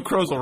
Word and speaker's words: Krozel: Saturday, July 18th by Joe Krozel, rhymes with puzzle.